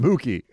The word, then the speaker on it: Mookie: Tell John it's pronounced Mookie.